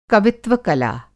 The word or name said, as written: कवित्व-कला